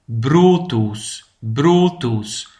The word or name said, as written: de brooddoos